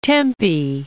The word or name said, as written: Tempe, Vale of